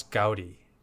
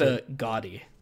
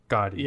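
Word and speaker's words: Gaudí: So you have always said that word wrong in our podcast, and I don't know if I should ever tell you that you're saying it wrong. I'm pretty sure it's pronounced Gaudi.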